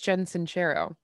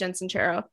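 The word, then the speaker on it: Jen Sincero: Also, just found out it's Jensincero. – Yeah, I think it is Jensincero because I listened to her audiobook, so I heard her say her name.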